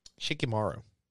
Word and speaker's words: Shikamaru: Shikamaru.